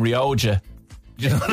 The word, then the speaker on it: Rioja: And the Spanish wine, Rioja, why do pub staffs pronounce it Rioja when it's Spanish? It should be Rioja con la Jota.